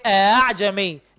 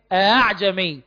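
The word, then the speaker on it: ءأعجمي: فمثلا تكون بينها وبين الألف في نحو أعجمي، كلمة أعجمي بننطقها الحفص بنقول إيه؟ هل بنقول أعجمي ولا بنقول أعجمي؟ لا كده ولا كده، أمال بنقول إيه؟ أعجمي، لا هي همزة ولا هي ألف، يبقى بنقولها إيه؟ أعجمي.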